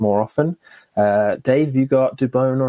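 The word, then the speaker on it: Dubón: I believe the commentators in San Francisco are now finalizing that that is how you pronounce his surname, Dubon.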